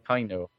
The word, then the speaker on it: Kaino: Is it Kaino or Kaino? But anyway. – Kaino.